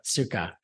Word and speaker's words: Sukkah: Sukkah.